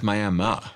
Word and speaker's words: Miami: It's pronounced Miami, even though it's spelled Miami.